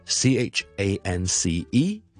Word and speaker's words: chance: 有听众问萧叔叔可唔可以讲一讲英式英文同美式英文个发音，其中最大分别嘅一类字就系咧 C H A N C E，机会咁嘅意思啦。英式英文系 chance，系 I am，而美式英文系类似 chance， OK，呢个分别系非常极之大嘅。